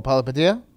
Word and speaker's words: Palihapitiya: Chamath Palihapitiya. – Oh, Palihapitiya? – Yeah.